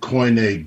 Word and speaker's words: Koine: Now, that word is spelled T-H-E-A-N-T-H-R-O-P-O-S, theanthropos. T-H-E-A-N-T-H-R-O-P-O-S, theanthropos. Now, what does that mean? That means the God-man in Koine Greek. Koine is spelled K-O-I-N-E, and that was the Greek language that Jesus spoke in that day, and the people spoke Koine Greek.